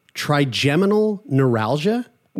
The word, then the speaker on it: trigeminal neuralgia: Trigeminal neuralgia.